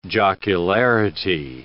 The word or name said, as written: jocularity